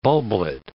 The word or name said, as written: bulblet